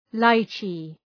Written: lychee

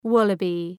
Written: wallaby

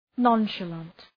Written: nonchalant

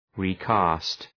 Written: recast

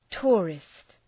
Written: tourist

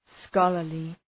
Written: scholarly

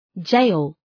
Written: gaol

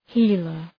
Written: healer

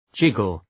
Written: jiggle

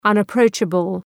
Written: unapproachable

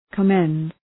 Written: commend

